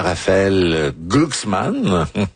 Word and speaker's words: Raphaël Glucksmann: Et Ségolène Royal a lancé dimanche une première salve contre le candidat Raphaël Glucksmann.